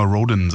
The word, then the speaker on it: Rhodan: Also bisher haben immer, wenn Perry Rhodan-Fans mit mir gesprochen haben, gesagt, ich würde es falsch aussprechen, weil ich immer Rhodan sage. Also scheint Rhodan richtig zu sein.